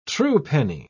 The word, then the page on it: truepenny: British English